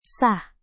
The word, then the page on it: sah: indonesian